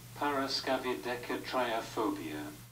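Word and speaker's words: paraskevidekatriaphobia: And that one is pronounced, hang on, give me a minute. I believe it is... – Paraskavidekatriaphobia. – How was that? – That was really good.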